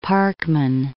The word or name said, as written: Parkman